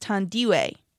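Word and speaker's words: Thandiwe: And her real name is spelled almost the same, but it has a W in it in between the I and the E of Thandie, and it's pronounced Tandiwe.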